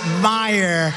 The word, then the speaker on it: Meijer: A guy who spells his name M-E-I-J-E-R, but they pronounce it Meyer. The hell kind of a spelling is that? Meyer. Meijer. It's Meijer, but it's actually pronounced Meyer.